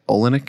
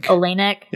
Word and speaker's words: Oleynik: Oleynik. – Oleynik.